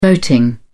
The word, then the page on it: voting: British English